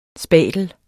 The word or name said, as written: spatel